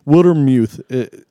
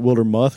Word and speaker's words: Wildermuth: He says his name very clearly. So Wildermuth. And if you look at it, you probably think Wildermuth because it's M-U-T-H, but he pronounces it the other way.